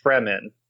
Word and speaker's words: Fremen: The general native population is called it's written Fremen, but it's very clearly supposed to be Freemen, but it's pronounced Fremen. I think.